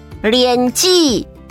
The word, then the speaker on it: 莲子: 莲子台语念作莲记，莲记。